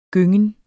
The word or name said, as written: gyngen